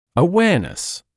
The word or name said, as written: awareness